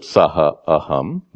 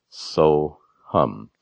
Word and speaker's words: soham: You don't pronounce it saha aham. Those are the words. Due to grammatical rules, you pronounce it soham.